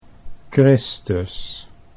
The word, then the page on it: Christus: Dutch